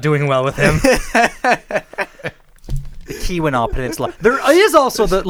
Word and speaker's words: Keweenaw: Well, I mean, if you're going to the the Leeuwinaw Peninsula, say that right? Keweenaw. Sorry.